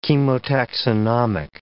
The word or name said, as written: chemotaxonomic